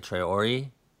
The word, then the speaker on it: Traore: Adam Traore. That's how you say his name, right? Traore. – Correct.